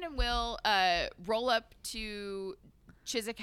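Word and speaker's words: Chiswick: But I'm saying Chizik because I don't think you pronounce the W, right? It's not Chizwick.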